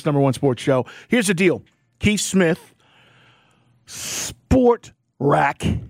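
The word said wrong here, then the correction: Spotrac: Well, we were talking about this, Keith, and you know, we've heard various pronunciations on this. It is Spot Rack.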